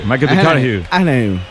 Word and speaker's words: Matthew McConaughey: ¿Matthew McConaughey? – Sí. – Me fichó, le gusta que hay que ir corrigiendo la pronunciación. – McConaughey. Matthew McConaughey.